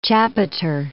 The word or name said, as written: chapiter